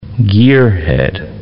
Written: gearhead

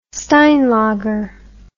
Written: steinlager

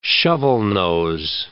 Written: shovelnose

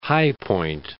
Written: High Point

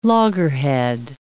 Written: loggerhead

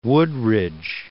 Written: Woodridge

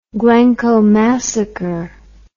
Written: glencoe massacre

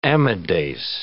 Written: amidase

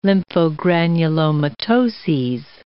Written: lymphogranulomatoses

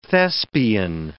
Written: thespian

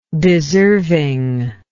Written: deservingness

